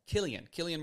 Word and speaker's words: Cillian: Cillian Murphy.